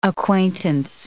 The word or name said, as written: acquaintance